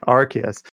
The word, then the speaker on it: Arceus: Arceus.